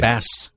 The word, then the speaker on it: Bas: Why are you pronouncing it Boz, though? – Right. – Probably like Baz or something. – Exactly. – Like Bass. – B-A-S, right? – Bass Rutten. – Boz would connote a Z, right? – Right.